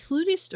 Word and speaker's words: flutist: We're back with is it flutist or flautist, Cheryl? How do you... – Either way. – Either way, there isn't a preference.